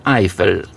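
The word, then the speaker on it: Eifel: To jest skraj mało może w Polsce znanej krainy Eiffel. Mówi się tak jak wieża Eiffla po polsku, chociaż pisze się inaczej.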